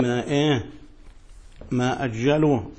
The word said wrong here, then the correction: عجلوا: قلبت المعنى تماما. ما عجلوا المغرب، أنت قلت ماذا قلت؟ أجلوا، أجلوا يعني أخروا، تبقى قلبت المعنى.